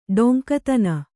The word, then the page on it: ಡೊಂಕುತನ: ḍoŋkutana